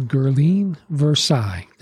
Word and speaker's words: Guirleine Versailles: And her name is Guirleine Versailles.